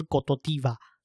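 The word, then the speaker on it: Côte d'Ivoire: 对啊，像那个象牙海岸嘛，台湾叫象牙海岸，可是它的它的国家发发音是用法语发嘛，所以发音发出来好像叫做戈多迪瓦，所以说它其实翻译的话，象牙海岸这个国家他们叫做科特迪瓦。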